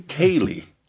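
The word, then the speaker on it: céilí: Did I pronounce that right? Sealy? The Sealy? West Side Fall, Sealy? – Kaylee.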